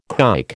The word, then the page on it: kike: American English